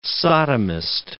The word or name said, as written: sodomist